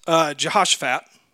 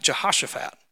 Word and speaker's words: Jehoshaphat: The king of Judah right at this point is Jehoshaphat, not Jehoshaphat, Jehoshaphat.